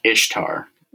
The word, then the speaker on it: Ishtar: Basically, worship of the pagan fertility god named Ishtar. Mm-hmm. That's how it's properly pronounced.